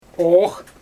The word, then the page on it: oox: Xyzyl